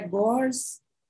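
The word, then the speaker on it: Gorz: Eu não vou saber falar a pronúncia. André Gor? André é, Gors. – André... – A pronúncia não ficou tão errada, então.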